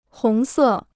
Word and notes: 红色: hóng sè

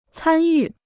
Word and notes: 参与: cān yù

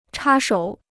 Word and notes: 插手: chā shǒu

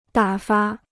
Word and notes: 打发: dǎ fa

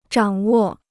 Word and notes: 掌握: zhǎng wò

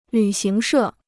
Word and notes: 旅行社: lǚ xíng shè

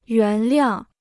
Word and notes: 原谅: yuán liàng

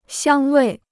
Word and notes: 香味: xiāng wèi

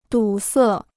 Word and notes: 堵塞: dǔ sè

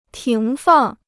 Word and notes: 停放: tíng fàng

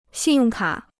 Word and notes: 信用卡: xìn yòng kǎ

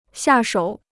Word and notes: 下手: xià shǒu

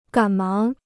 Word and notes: 赶忙: gǎn máng

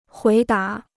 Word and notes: 回答: huí dá

